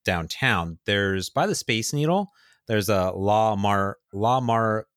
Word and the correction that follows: La Marzocco: La Marzocco.